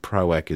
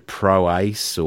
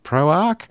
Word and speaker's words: Proac: And, of course, you hear people say it differently, and I'm hoping that Proac is how you pronounce it, like, because a lot of people turn it into Proace or Proac. – Yeah. – Proac? – Yeah. – But it is. – It is Proac, yes.